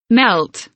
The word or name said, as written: melt